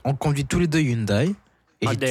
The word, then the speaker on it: Hyundai: En français, Hyundai, donc, se prononce Hyundai. Le H initial est muet. Le Y se lit comme un I appuyé et la finale se prononce comme le son D.